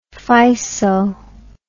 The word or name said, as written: physo